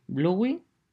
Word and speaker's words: Bluey: Todo el mundo está viendo la de Bluey.